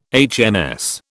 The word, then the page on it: HMS: British English